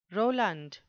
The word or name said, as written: Roland